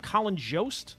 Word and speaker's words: Colin Jost: Jimmy Buffett actually did save the life of Colin Jost. Is that how you say his name? Colin Jost? – Yes.